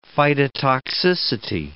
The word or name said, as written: phytotoxicity